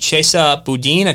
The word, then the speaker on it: Boudin: There is a very radical, far-leftist, Soros-backed AG in San Francisco named Chesa Boudin.